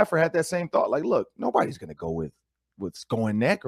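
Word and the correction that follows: Skowronek: or whatever, how you say his name, Ben. Skoronek. There it goes.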